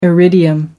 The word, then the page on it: iridium: American English